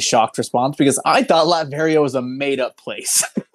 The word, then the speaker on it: Latveria: Just to clarify, while you were recapping, I looked up Latveria, and I do believe it is pronounced Latveria.